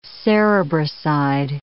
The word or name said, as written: cerebroside